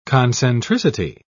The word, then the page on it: concentricity: British English